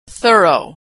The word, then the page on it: thorough: American English